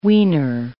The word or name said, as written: weaner